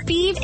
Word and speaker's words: Fives: Thebe is spelled F-I-V-E-S, like the number, but pronounced Thebe.